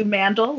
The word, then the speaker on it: Mandl: It's Marielou Mandl, like handle.